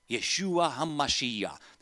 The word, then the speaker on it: Yeshua HaMashiach: Yeshua HaMashiach. That's how you say it in Hebrew.